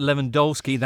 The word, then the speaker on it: Lewandowski: A hat trick for Robert Lewandowski, that's how it's pronounced, who's now only the second player to score 300 Bundesliga goals ever after Gerd Müller.